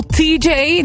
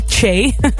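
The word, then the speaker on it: Lil Tjay: En wat dacht je van Lil TJ, die je eigenlijk dus uitspreekt als TJ en niet Che?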